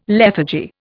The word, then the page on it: lethargy: Indian